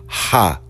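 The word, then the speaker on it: rapé: And the brother was doing a ceremony that's spelled R-A-P-E-H, but it's pronounced Hape, H-A-P. E or H-A-P-A-H, Hape.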